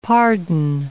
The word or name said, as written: pardon